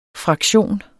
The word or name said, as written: fraktion